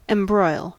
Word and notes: embroil: American English

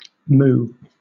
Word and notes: moue: British English

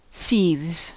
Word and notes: seethes: American English

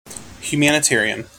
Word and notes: humanitarian: American English

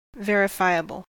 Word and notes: verifiable: American English

